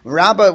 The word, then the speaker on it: Rabba: Rabba, when pronounced is with a hay at the end, I say bays. And when it's Rava, I say with a vav. Okay, anyway. You know, pronounced like a vav.